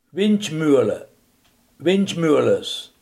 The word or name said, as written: windmolen